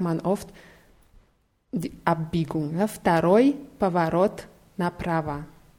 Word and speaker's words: второй: Dieses W wird aber als F ausgesprochen. Второй.